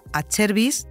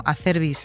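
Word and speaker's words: Acerbis: Eh, se cumple este año los 50 años de la mítica marca Achervis Acervis en italiano, Acervis es como lo pronunciamos aquí, en España.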